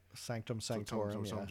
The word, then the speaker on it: Sanctum Sanctorum: They at the Sanctum Sanctorum. I'm saying that right? – Sanctum Sanctorum.